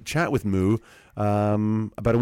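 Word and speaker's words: Mu: The openers include Summering and Gang Signs, but it's actually an album release of the duo Moo, which is spelled M-U.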